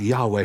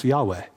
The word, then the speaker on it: Yahweh: Do you remember how you pronounce Yahweh? You don't use your lips and you don't use your tongue. It's just breath.